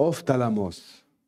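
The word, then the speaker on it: ophthalmos (ὀφθαλμός): The word eyes in the Greek is ophthalmos. Right? I think I'm pronouncing it better than Tammy. Uh, ophthalmos. Sounds, sounds like it.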